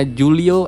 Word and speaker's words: Julio: Ada beberapa orang-orang yang kayak misalkan contoh orang-orang dari Spanyol dan Italia itu kan huruf J diganti bacanya huruf H gitu ya. – Eh, emang iya ya? Iya. – Contohnya gini kayak namanya Julio Iglesias.